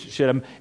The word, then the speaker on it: shittim: What they're burning in Hebrew is spelled S-H-I-T-T-I-M. Well, that word, and it is pronounced exactly that as it's shittim, is a Hebrew word, and it means acacia.